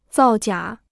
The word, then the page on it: 造假: zào jiǎ